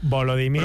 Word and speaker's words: Volodymyr: Volodymyr. ¿Pedimos deletreo o lo aceptamos? – Bien. Con lo aceptamos. Volodymyr.